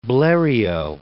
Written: Bleriot

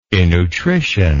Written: innutrition

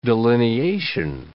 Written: delineation

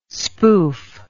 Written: spoof

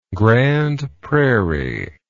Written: grande prairie

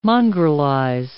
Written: mongrelize

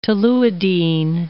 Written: toluidine

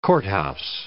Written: courthouse

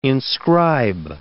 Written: inscribe

